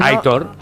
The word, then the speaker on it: Aitor: Es un oyente que dice, por favor, que Aitor se pronuncia como se lee. – Sí, ¿no? – Aitor. – La acentuación es en la O.